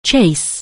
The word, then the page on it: chase: anglais